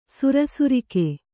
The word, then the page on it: ಸುರಸುರಿಕೆ: sura surike